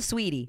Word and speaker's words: Saweetie: Just regular Saweetie.